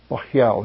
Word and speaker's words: Baal: as they taught my people to swear by Baal, is literally how it's pronounced, Baal in Hebrew.